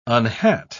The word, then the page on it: unhat: British English